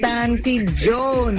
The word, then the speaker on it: Tanti Joan: DJ Inshan, this is Tanti Joan. You've been pronouncing my name wrong from long time. It's Tanti Joan.